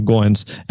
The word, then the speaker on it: Goins: He told me from the beginning that when we first met, his last name rhymes with coins. So Goins.